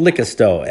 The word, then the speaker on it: Licasto: And then after that, I started a band called Licasto, L-I-C-A-S-T-O, as in liquor store, but pronounced with a little bit more East Oakland in it.